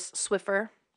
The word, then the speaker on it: Swiffer: Swiffer, quizás dices eso, es Swiffer, es la pronunciación.